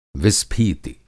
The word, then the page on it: विस्फीति: Hindi